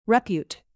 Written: repute

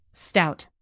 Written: stout

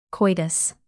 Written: coitus

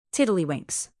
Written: tiddlywinks

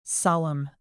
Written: solumn

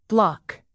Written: bloc

